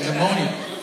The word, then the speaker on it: pneumonia: I said, pneumonia? No, it's pneumonia. I said, you don't say the P.